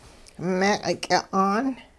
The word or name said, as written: meeq'-ky'a'aan